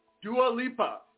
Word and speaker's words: Dua Lipa: I said it wrong. All right, guys, so that was, what is it again? Come say it. – Dua Lipa. Dua Lipa.